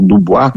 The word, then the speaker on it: Du Bois: Algumas pessoas falam W.E.B. Du Bois, ou falam com a pronúncia mais afrancesada, que é o Du Bois, William Edward Burrell Du Bois, ou Du Bois, que era a NAACP, National Association of Advanced of Colored People.